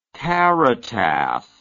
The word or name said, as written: caritas